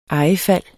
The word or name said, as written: ejefald